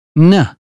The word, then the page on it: name: British English